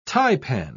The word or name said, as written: taipan